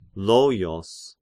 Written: Loios

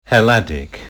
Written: Helladic

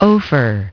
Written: Ophir

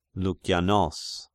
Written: Loukianos

